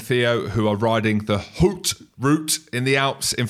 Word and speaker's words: Haute: All you need to do is Google Tom Atkinson at Hoot route. It's spelled H-A-U-T-E. So if you're from Cove, Scotland, maybe sounds different, but it's the Hoot route Alps and you can donate.